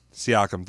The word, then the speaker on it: Siakam: Siakam, thank you.